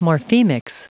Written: morphemics

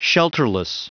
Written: shelterless

